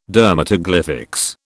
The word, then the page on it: dermatoglyphics: British English